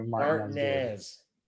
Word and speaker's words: Martinez: That is, while spelt Martinez, that is Martinez, Georgia, which is actually kind of near where I live now. I live near Martinez.